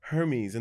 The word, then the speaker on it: Hermes: And it was for his role as Hermes, am I saying that right? – Yeah. – Hermes in the musical Hadestown.